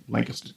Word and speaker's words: Lancaster: That's how they pronounce it down there, Lancaster, Texas.